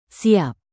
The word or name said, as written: siap